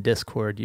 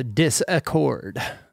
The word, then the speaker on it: Discord: Love the added syllable in Discord. You turned it into Disaccord.